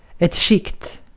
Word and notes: layer: [ˈleɪ.ɚ]; /ˈleɪ.ə/; /lɛə/; /ˈleɪ.ə(ɹ)/

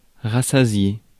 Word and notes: rassasié: France; /ʁa.sa.zje/

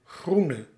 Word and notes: grün: [ɡʁyːn]; /gryːn/; /ɡryːn/

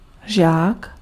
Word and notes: disciple: American English; [dɪ.ˈsaɪ.pəl]; /dɪˈsaɪpəɫ/

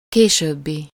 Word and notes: suivant: France; [sɥi.vɑ̃]

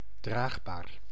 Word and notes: draagbaar: /ˈdraːɣbaːr/

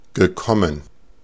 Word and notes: gekommen: [ɡəˈkɔmən]

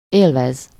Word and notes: jouir: France; [ʒwiʁ]

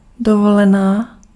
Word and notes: vacances: /va.kɑ̃s/

